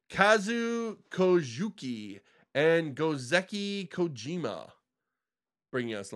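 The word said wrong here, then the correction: Kazuo Koike: Let's all forgive that pronunciation. – Yes. – Really? You can do better? – Kazuo Koiki. – Uh-huh, which is what I said. – And no, you didn't. You added some letters there.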